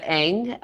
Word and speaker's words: Ng: In Cantonese, you know, that Ng is spelled, the character is the same as Wu. So okay. – It's funny because in Mandarin, it would be Wu, and in Cantonese, mm-hmm. it's pronounced Ng.